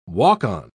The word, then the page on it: walk-on: British English